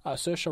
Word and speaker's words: Saoirse: We've got Best Actress in a Film for Drama, Cynthia Erivo for Harriet, Scarlett Johansson for Marriage Story, Saoirse Ronan for Little Woman.